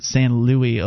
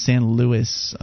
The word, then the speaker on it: San Luis: I'm being told that you don't actually pronounce it San Luis Obispo. It's San Luis, apparently.